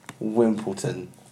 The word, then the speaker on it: Wimbledon: It's kind of how it's spelt. Wimbledon. – Yeah. Yeah, there's no T there. – It's got no T in it, and it's got no P in it.